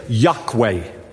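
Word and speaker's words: Yokwe: Just tell the person by the side of you, say, Yahweh. Say it one more time. Say, Yahweh. It is spelled Y-O-K-W-E. Y-O-K-W-E. That's how they spell it.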